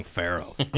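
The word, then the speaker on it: Pharoahe: Pharaoh Monch is a hip-hop guy. I said it wrong. Pharaoh. Damn it.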